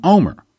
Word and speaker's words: Olmert: See, because you pronounce Ehud's Omer's last name Omer, but it's spelled O-L-M-E-R-T, but it's pronounced Omer.